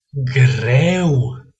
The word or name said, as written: greu